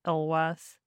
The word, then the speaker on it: LLWAS: So this is an LLWAS mast, which may be pronounced LWAS. I have no idea. – I'm pretty sure it's pronounced LWAS.